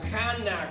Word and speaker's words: Hannah: Ana, o Hannah, como se podría decir o pronunciar en hebreo, podríamos llamarla como la madre de la fe.